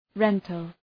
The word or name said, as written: rental